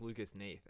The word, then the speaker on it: Lucas Hnath: Ooh, it's pronounced Lucas Nath, actually. Sorry, we never discussed that. Lucas Nath.